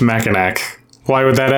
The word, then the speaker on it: Mackinac: Chris rang in and said, what is Mackinac Island? Which you would think that's how it's pronounced because it does end with a C, but they say it Mackinac.